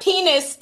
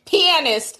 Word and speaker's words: pianist: He said, not penis, T, it's pianist. Oh, did I mispronounce the word? Oh, sorry. So pianist, not penis.